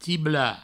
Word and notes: tiblar: tibla

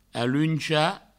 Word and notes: aluenchar: aluencha

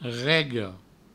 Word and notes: rega: rego

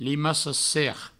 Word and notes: limaca-serp: provençal; limaco-serp

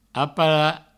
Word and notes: aparar: apara

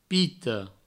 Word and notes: pita: pito

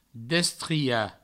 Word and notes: destriar: destria; Provençal

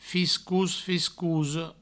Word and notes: fiscos, fiscosa: fiscous, fiscouso; Provençal

